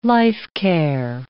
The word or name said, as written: life-care